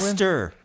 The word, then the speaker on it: Leicester: From Dan Foss, 22, from Leicester. Leicester, England? Leicester. You've butchered it, my friend.